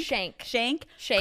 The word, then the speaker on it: Shayk: Shayk could look good.